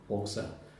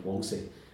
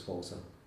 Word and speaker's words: Walser: Okay, this is McDermott on Walser. I believe that's how it's said in Shetlantic. I intend to say from the spelling Walsy, but in Shetland it's Walser.